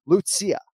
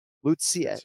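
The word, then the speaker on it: Lucie: So the first name is 3 syllables, right? So she's been out of the UFC for like almost 3 years. So I found my file 6 fights ago, and I actually had the phonetics written on the file, which means it's one of the more tricky ones, but it's Lucia. Lucia. It's 3 syllables with the first name.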